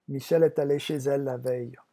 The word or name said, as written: Michelle